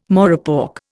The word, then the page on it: morepork: Indian